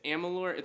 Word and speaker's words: Amalur: You got the name right. It's Amalur. It's really AMALUR.